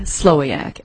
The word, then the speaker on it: Slowiak: Romy, R-O-M-I, Slowiak, S-L-O-W-I-A-K.